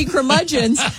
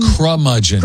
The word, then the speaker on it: curmudgeon: Say curmudgeon. – Curmudgeon. – See, yeah. I'm kind of... – Crumudgeon. – Curmudgeon. – So it is a K.